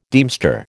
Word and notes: deemster: American English

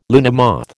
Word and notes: luna moth: American English

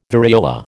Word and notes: variola: American English

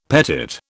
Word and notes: petit: British English

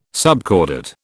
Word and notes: subcordate: British English

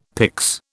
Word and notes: pyx: British English

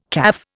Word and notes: caff: Indian

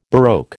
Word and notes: baroque: American English

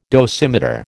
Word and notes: dosimeter: American English

